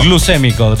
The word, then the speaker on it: glucémico: ¿Cómo se dice esa palabra? ¿Glucémico? O sea, usted que es médico. – Glucémico. Glicemia o glicémico puede ser.